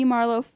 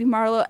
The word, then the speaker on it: Marlowe: Oh yeah, I'm on YouTube under the name Phoebe Marlowe, Facebook under the name Phoebe Marlowe, and now Instagram under the name Phoebe Marlowe.